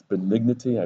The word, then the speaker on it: benignity: Okay, but I actually did the thing to pronounce it. It's benignity. I thought it's benignity.